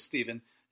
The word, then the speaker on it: Stephen: Anyways, my name is Stephen. It's spelled with a PH.